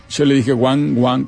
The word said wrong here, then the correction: Wang Gang: pero no es Wang y después Gang, el embajador chino.